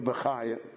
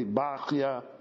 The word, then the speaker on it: Bachya: And therefore, this book, the Chovet HaLevavot of Rabbeinu Bechaya, so we don't know how to pronounce the Ashkenazim pronounce it Bechaya, it's probably Bachya.